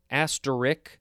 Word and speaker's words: asterisk: It is asterisk, A-S-T-E-R-I-S-K. It's not asterisk or some other way to pronounce it.